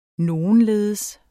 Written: nogenledes

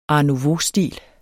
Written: art

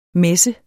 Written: messen

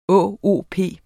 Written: ÅOP

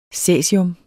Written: cæsium

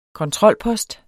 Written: kontrolpost

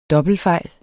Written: dobbeltfejl

